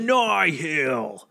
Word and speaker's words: Nihil: Maybe not Nye Hill stuff, but something a little more interesting, you know, a better. – Listen, you got to say it right. It's the Nihil.